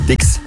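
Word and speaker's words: Styx: Die Band heißt Stix. Schon richtig ausgesprochen.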